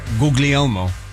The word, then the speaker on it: Guglielmo: Guglielmo. – Guglielmo? – Right. Yes. – Guglielmo. – Sesame Street? – Guglielmo. – Guglielmo? – Yes. Like googly-eyed Elmo, yes.